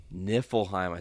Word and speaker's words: Niflheim: Can you pronounce that word? – I think it's Niflheim. – Niflheim. I think you're right.